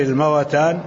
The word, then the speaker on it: الموتان: لما قلت الموتان ليست الموتان، هي بالتحريك خلاف الحيوان، يقال اشتري الموتان ولا تشتري الحيوان.